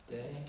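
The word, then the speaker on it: De (δέ): De. De. Okay. De. Good. De. So a, the eta would be the long e sound. So with that, epsilon, it's a short sound.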